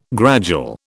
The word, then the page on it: gradual: British English